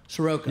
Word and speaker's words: Soroka: Soroka? – Soroka.